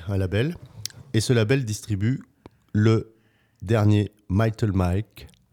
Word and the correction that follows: Malted Milk: Je le prononce bien. – Malted Milk. – Malted Milk. Décidément, tu vois, je suis tombé dans le panneau. – C'est avec l'accent.